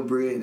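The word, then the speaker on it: Brey: Follow me on Twitter, Instagram, at Bray, Duh Bray.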